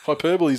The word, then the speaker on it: hyperbole: Yeah, well, he's already signed to 2015, so all the hyperbole and everything that's carrying on about him playing the hyperbole, see? You can pronounce things like shit too. Hyperbole is the word you're looking for. Hyperbole.